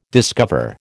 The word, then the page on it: discover: American English